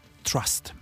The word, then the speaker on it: TRST: No bo z Kanady pochodzi zespół Trust, a właściwie Robert Alphons, który stoi za projektem TRST, czyli TR łamane przez ST, ale wymawiane zwykle jako Trust.